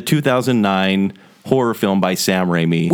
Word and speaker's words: Raimi: Sam Raimi.